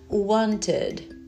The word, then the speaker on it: wanted: É por isso que esse somzinho de vogal no meio, ele tá ali né, para poder a pronúncia ficar ok. Então seria wanted. Wanted. Aí